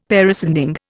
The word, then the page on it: parascending: Indian